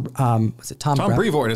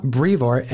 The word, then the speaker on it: Brevoort: Tom Brevoort is how Donny Cates pronounced it. – Yeah, Tom Brevoort.